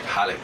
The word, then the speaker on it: Khalik: Khalik is Arabic. I think it's pronounced like Halik.